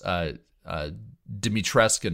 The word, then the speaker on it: Dimitrescu: But yeah, with this, the villains seem, I mean, everybody loves Dimitrescu, and everybody in my YouTube comments corrected me that it is actually that you do pronounce the U.